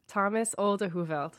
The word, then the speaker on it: Thomas Olde Heuvelt: It is Echo by Thomas Olde Heuvelt. Um, thank you for the pronunciation guide in the notes. yeah, Thomas Olde Heuvelt.